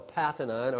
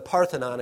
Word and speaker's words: Parthenon: The great temple to Athena, thus the name Athens, which was called, and it's still there today, I mean, it's called the Parthenon, or the Parthenon, I should say. Forgive me for my pronunciation. The Parthenon.